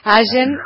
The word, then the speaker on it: Allen: Gracias por comunicarte y saludos a toda la gente de Allen, o ¿cómo se pronuncia? – Allen en Río Negro.